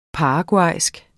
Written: paraguaysk

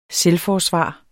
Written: selvforsvar

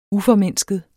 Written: uformindsket

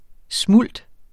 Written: smult

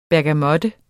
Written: bergamot

